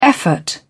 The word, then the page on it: effort: British English